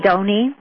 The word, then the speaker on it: Donnie: Donnie. It's pronounced like pony.